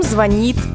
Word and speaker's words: звонит: Ударение всегда на последний слог. Например, по ком звонит колокол. Клиент звонит по телефону.